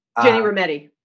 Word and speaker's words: Ginni Rometty: Ginni Rometty.